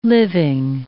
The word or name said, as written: living